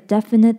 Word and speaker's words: definite: 那例句会是, no one can make a definite decision before he comes back. 在他回来前,没有人能做一个明确的决定。这边要注意define跟definite这两个字的发音。首先define是重音在fine, define,后面的fine这个字是重音。那另外是definite,definite的重音是在前面, definite。 Definite. 另外要注意的是, 这两个字都是DE, 但这两个DE的发音却不同。 Define的DE是E, define. 另外definite的DE是E, definite.